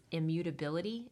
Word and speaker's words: immutability: Well, I'm really looking forward to jumping into this because we're going to be talking about and learning about God's self-existence, His immutability. Am I saying that correctly? – Yeah.